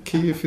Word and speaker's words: Kyiv: Have I pronounced it correctly? Kyiv. – Yeah, that's right. Good pronunciation. Kyiv is the capital of Ukraine and north part and some kind of central part of Ukraine.